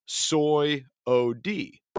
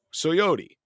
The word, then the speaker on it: Swyody: Swody, S-W-Y-O-D-Y, pronounced Soy-O-D, Soyody, right?